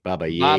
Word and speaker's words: Baba Yaga: Baba Yaga. – Baba Yaga. Cut Man G live not baby. – In the studio. – Levi, it's not baby. – Yep, Baba.